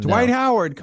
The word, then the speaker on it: Dwight Howard: D. White Howard. – How do you say his name like that? – Dwight Howard, come on, man.